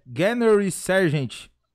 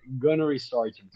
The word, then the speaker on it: Gunnery Sergeant: Tem uma pronúncia aqui que eu tava até perguntando pro Daniel é Gannery Sergeant, que é um. É isso? Fala. Puta, não sei pronunciar. Pronunciar. – A, a pronunciação é Gunnery Sergeant, mas é.